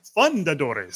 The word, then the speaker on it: Fundadores: So it's Fundadores. So it's, they put the emphasis on the fun when they merchandise it.